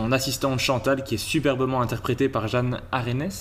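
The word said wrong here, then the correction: Arènes: Jeanne Arène.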